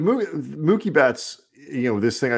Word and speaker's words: Mookie: No, he's saying Mookie now, but he was for a very long time saying Mucky Betts. – Mucky Betts. I mean, we should have just kept him saying that and never told him that he was saying it wrong, because that's way funnier.